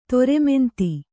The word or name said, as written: ದರ್ಮಾಂಧ